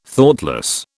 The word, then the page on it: Thoughtless: British English